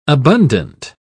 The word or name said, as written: abundant